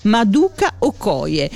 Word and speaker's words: Maduka Okoye: Allora, ascoltami bene, così come è scritto, così come lo pronuncio, Maduka, ma con la K, Okoye, e sempre con la C e con la K, Maduka Okoye.